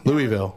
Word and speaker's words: Louisville: Is that how it's pronounced? – Louisville. – Louisville.